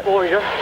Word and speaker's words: Boyer: Boyer. – Spell it. – B-O-Y-E-R.